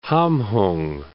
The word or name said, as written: Hamhung